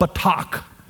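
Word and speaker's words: batak (בטח): Now that phrase trust in the Lord if you understand the Hebrew the way the Hebrew language is shaped, this is an emotional word called batak. Batak, and I just butchered that with my southern accent, but that's how you say it.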